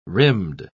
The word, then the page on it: rimmed: British English